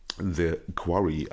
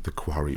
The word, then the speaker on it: The Quarry: Nochmal danke an den Hinweis, der von der letzten Folge kam, dass ich äh, das falsch ausgesprochen habe. Es heißt natürlich The Quarry und nicht The Quarry.